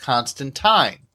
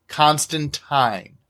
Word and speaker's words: Constantine: Although I refer to the character here as John Constantine, my understanding is that Alan Moore, who created the character, intended for the name to be pronounced Constantine. What's more, over the years, the character himself has, on several different occasions, stated that his name is correctly pronounced in the latter fashion.